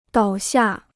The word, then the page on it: 倒下: dǎo xià